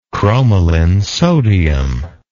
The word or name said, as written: cromolyn sodium